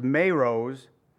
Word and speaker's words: Meroz: So even though we don't know the exact location, it's actually pronounced Meroz.